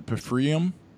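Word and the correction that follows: pFriem: It's Freem. Yeah.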